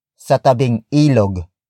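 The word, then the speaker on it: sa tabing-ilog: If they are moving the house to a lot by the river, your answer is sa tabing ilog. Sa tabing ilog.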